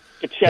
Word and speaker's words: Kochetkov: This kid in goal, Kochetkov, I think that's the way you pronounce his name, Kochetkov,